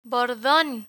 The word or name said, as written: bordon